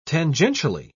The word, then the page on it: tangentially: British English